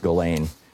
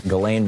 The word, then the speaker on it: Ghislaine: And then, of course, there's Ghislaine Maxwell, I think it's pronounced Ghislaine, even though there's an S in it, Ghislaine Maxwell, who is in prison now in Florida.